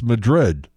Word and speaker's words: Madrid: Anybody know how to pronounce this? Well, it's Madrid. Oh, New Madrid.